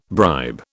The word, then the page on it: bribe: British English